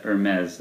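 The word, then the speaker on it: Hermès: So for those of you that didn't hear that David said Hermes, and that's the actual way that it's pronounced.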